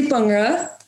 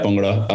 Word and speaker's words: Bhangra: Um, I say Bangra. How do you say it? – Yeah, Bangra. Um, so it's like instead of like a BH, it's more of like a P.